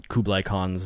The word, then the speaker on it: Kublai Khan: who was his Kublai Khan's vice regent.